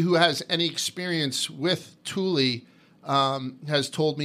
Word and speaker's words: Thule: I kept on calling it Thule, but it's Thule.